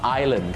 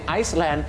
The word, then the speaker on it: island: Island ก็พอครับถ้าไปออกเสียง Iceland